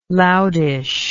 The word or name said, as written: loudish